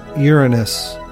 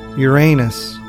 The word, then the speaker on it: Uranus: The pronunciation of the name Uranus, preferred among astronomers, is Uranus, with the stress on the first syllable, as in the Latin Uranus, in contrast to the colloquial Uranus, with the stress on the second syllable and the long A sound, though both are considered acceptable.